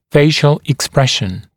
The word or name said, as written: facial expression